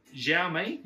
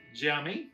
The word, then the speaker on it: Xiaomi: Xiaomi, I think it's Z-X-I-A-O-M-I, Xiaomi. Yeah, I'm pretty sure that's how they pronounce it.